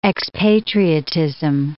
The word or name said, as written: expatriatism